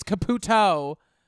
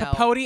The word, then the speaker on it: Caputo: It's not even spelled the same. It's Caputo. – Yeah, but, you know. – Capote and Caputo.